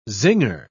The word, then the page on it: zinger: British English